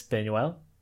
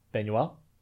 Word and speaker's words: Buñuel: But anyways, The Exterminating Angel is a 1962 Mexican supernatural surrealist film written and directed by Luis Buñuel. – How do you say his name? – Buñuel.